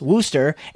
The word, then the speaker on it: Worcestershire: I live in New England where Worcestershire is pronounced Wooster and car is pronounced ca, so I know all about regional dialects.